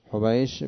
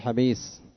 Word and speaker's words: حبيش: حبيش بضم الحاء المهملة وفتح الباء الموحدة وسكون المثنات التحتية فشين معجمة. حبيش، كل هذا الآن السطر ناجل يضبط لنا حبيش أنها هكذا تنطق، حتى ما يأتي شخص ويقول حبيش، وآخر يقول حبيس، ثالث يقول حنبش، رابع يقول حيتش، هكذا لا، يضبطها بضم الحاء المهملة حو، وفتح الباء الموحدة حبي،